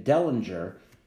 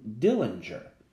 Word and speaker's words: Dellinger: For example, one of the Chicago Seven, David Dellinger, Judge Hoffman kept calling him Dillinger.